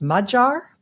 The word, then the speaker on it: Magyar: But that's, according to Mr. Google, the proper pronunciation is Magyar.